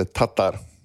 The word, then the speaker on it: Tatar: Eller Tatar på slovakiskt uttal.